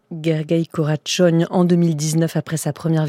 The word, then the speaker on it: Karácsony: ça s'écrit Karácsony. Donc voilà, on dit Gergely Karácsony, qui défend, donc on l'entend, Brunto, une politique écologique et sociale.